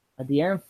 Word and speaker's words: De'Aaron: What about DeAndre Fox? – De'Aaron Fox? – Ooh. De'Aaron Fox. I always get that name wrong.